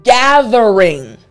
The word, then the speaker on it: gathering: Now, I want you to pronounce it like a native English speaker. So again, the word gathering, G-A-T-H-E-R-I-N-G. Now, in the middle of this word we have, well, at the beginning, kind of, T-H.